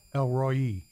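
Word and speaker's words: El Roi: Isn't that Elroy? – Elroy, yeah.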